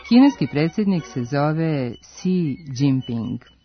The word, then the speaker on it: Xi: Prema Pinyin transkripciji, njegovo prezime počinje latiničnim slovom X, koje se u tom slogu, XI, na kineskom izgovara kao sasvim blago umekšan glas S, dok je I pod akcentom koji je nalik na naš dugouzlazni.